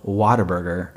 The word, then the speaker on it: Whataburger: I will say it's interesting to me that Texans pronounce it Whataburger as in water. But I always just think Whataburger. Like, because wow, Whataburger.